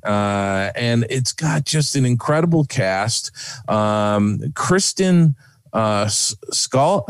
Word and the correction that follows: Schaal: I can't pronounce her last name. Scholl? – I've got it. Stand by. It's Kristen Schaal as number Schaal.